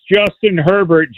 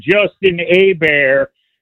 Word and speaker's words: Herbert: You had a bozo call about 10 minutes ago that pronounced Justin Herbert Justin Hebert.